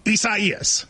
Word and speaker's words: Isaias: A lot of people, you know, prayers to everybody involved, but it is Isaias. – Is that how you say it, though? – You do. And it's hard because it's a fun name. And so you want to be like Isaias.